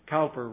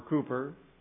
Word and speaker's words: Cowper: William Cowper wrote, or Cooper, depending on how you want to pronounce his name.